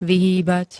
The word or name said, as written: vyhýbať